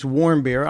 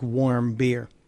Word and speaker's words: Warmbier: And then the weird part happens where this guy, this American, whose last name is Warmbier, Otto Warmbier, and it's spelled W-A-R-M-B-I-E-R. It's not like warm beer, but it's pronounced that way.